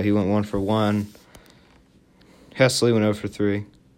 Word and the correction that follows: Haseley: Haseley. I would say Adam Hesley. It's Adam Haseley.